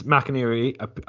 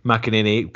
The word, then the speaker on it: McInerney: Well, the next thing we've got to talk about is after James McInerney, I've said his name wrong. – McInerney, great, you must have been practicing, appeared on our podcast calling for the SQA and Education Scotland to be disbanded.